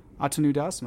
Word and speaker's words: Atanu Das: Atanu Das, am I saying that right too? – Yes, but just please call me Das.